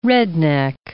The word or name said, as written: redneck